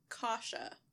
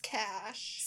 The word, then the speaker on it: Cache: When I read it, I read... Kasha, but that is C-A-S-H. – Right. Cash. See, my initial, when I very first read the message, I also said, oh, Kasha.